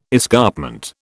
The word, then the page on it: escarpment: British English